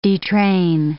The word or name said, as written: detrain